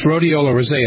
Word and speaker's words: Rhodiola rosea: Rhodiola rose, is it pronounced rosea? How do you pronounce rosea? That? – Well, yes, rhodiola rosea.